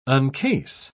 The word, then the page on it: uncase: British English